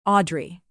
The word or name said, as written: audrey